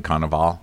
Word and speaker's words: Cannavale: Doesn't the dad look like Bobby Cannavale?